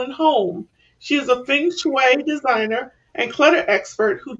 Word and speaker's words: feng shui: Feng shui, okay.